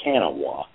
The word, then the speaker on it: Kanawha: It's kind of spelled Kanawha, but everybody pronounces it simply the Kanawha.